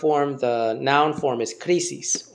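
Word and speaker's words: krisis (κρίσις): So after this, then the judgment, and the word judgment is krisis. And that's the word for judgment, krisis. It's, if you read it in English, you would pronounce it crisis.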